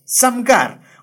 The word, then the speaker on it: Samgar: Sí, lo dije bien, Samgar.